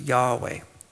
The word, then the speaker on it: Yahweh: But in ancient times, the Vav was pronounced W. And so it is believed that this name is pronounced Yahweh.